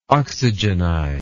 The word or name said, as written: oxygenize